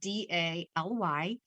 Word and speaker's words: Daly: And my last name is spelled D A L Y.